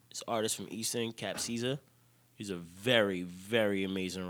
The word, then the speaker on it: Capcizza: It's spelled C-A-P-C-I-Z-Z-A. – Cap Caesar.